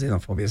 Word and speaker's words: xenophobia: The xenophobia is spelled with an X. But needless to say, it's still pronounced xenophobia.